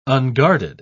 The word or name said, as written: unguarded